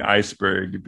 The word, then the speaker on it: Iceburg: and people called me Iceberg because of that, which is why it's spelled B-U-R-G and not B-E-R-G.